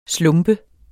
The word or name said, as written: slump